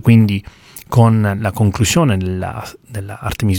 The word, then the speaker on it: Artemis: E in generale l'architettura Artemis, io mi leggo alla pronuncia americana perché vivo a Houston, sono abituato a pronunciarla in questo modo, dico Artemis non perché lo preferisca, solo perché sono abituato a sentirlo pronunciare Artemis.